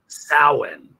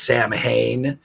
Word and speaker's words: Samhain: Samhain. Samhain is how it's pronounced. The way most people would pronounce it is Samhain. – That's what it looks like. – Probably because of Glenn Danzig's band from back in the 80s. But no, it's pronounced Samhain. It's like ancient Gaelic, that kind of thing.